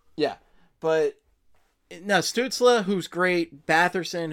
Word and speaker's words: Stützle: Is that how it's pronounced, Stützle? – Stützle, yeah. – Yeah, I thought it was Stutzel, like you're going to eat a Stutzel or something like that.